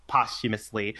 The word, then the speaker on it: posthumously: Well, yeah, or at least, yeah, there could be more, but posthumously, which I just learned how to pronounce that word. I always said the H that you shouldn't say, apparently.